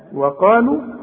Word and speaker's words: وقالوا: ها وقالوا اهي حرف عله ما قولوش برضه اكتبوا وما انطقوش